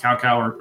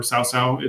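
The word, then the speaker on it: Cao Cao: So like the blue faction, I believe it's pronounced Cao-Cao or Cao-Cao. It's C-A-O-C-A-O.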